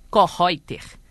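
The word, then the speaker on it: Koellreutter: Ele foi assistente do renomado compositor alemão Hans Johann Joachim Kornreuther. Falei certo, Ivan? – Falou certo.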